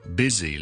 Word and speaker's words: busy: 今日咧，我哋再睇多几个，虽然串字咧系有 S 字母嘅，但系我哋咧要读成为 Z 嘅字。上次我哋讲咗 visit 啦、 easy 啦、 busy 啦呢三个字，仲有一啲字咧，我哋 Z 嘅，就系譬如 lose， lose to lose something。